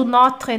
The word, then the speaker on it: Notre: A pronúncia do notre não é mais fechada? Notre. Uh, not, notre Dame, Notre. É, um pouquinho mais fechada.